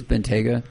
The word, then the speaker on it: Bentayga: Bentley Bentayga? – Mm-hmm. – I got to review one. – Is it Bentayga on three wheels. – It's Bentayga. T-A-Y-G-A.